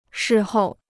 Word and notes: 事后: shì hòu

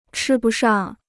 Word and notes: 吃不上: chī bu shàng

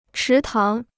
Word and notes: 池塘: chí táng

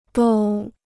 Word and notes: 都: dōu

